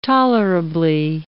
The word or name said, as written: tolerably